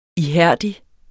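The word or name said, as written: ihærdig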